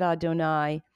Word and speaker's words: Adonai: You do two little yuds, Adonai, and you pronounce it Adonai.